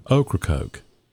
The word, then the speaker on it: Ocracoke: You know, I was watching this video yesterday about these northerners that came down to Ocracoke. – Uh-huh. – And they were trying to figure out how to pronounce it. And the sign said, say okra and then coke. Ocracoke.